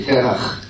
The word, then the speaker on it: Terach: By the way, you have to be able to pronounce the Terach. You say Terach. I'm not hearing the... There you go.